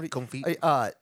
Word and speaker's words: confit: It was a confit. Is that how you say it? – Yeah, confit.